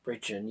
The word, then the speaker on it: Bridgen: I think that's how you pronounce it, Bridgen, yeah.